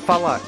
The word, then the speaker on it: Falak: That was a track by King Tetris called First Date, and it's produced by Falak. And you spell that F-A-L-A-K, Falak, or Falak.